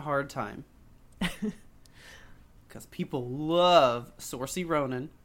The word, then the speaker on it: Saoirse: I had to look up how to pronounce her name. It's Saoirse. – Saoirse? – Yeah. – Oh. – I always called her Saoirse. – I even thought I was saying it right. – Saoirse Ronan. No, apparently it's Saoirse. I don't know what nationality that is, but... – She's Scottish, I think. – Scottish. Saoirse. It's like Sasha, but drunk.